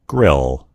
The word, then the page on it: grill: American English